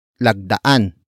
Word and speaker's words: lagdaan: Word for word it's lagdaan ang papeles.